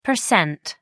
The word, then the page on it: pct: American English